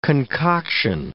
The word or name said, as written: concoction